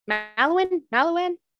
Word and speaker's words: Mallowan: She became friends with archaeologists Leonard and Catherine Woolley, as well as their assistant, Max Mallowan. Mallowan? Do we know? – You pronounced it correctly.